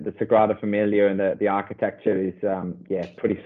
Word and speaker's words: Sagrada Familia: the Familia Grada, if I've pronounced that correctly. You're Spanish. Spanish is probably better than me. – Sagrada Familia. – Yes, yeah, that's it.